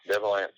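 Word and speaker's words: Devil Anse: which was also known as Devil Ants. Am I saying that right? – That's correct. Devil Ants.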